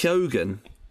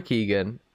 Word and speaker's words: Keoghan: I think it's Keoghan. I don't know how it's. I think it's Keoghan because everyone pronounces it. Some people say like Cohen. And it's like, well, it's not Cohen.